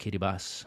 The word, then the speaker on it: Kiribati: It looks like Kiribati, but it's pronounced Kiribati.